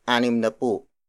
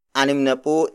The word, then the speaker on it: animnapu: For 60, the Tagalog word is anim na po. Anim na po. Anim na po. Animnapu is spelled as A N I M N A P U.